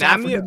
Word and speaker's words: Namibia: Nambia. – That's Namibia.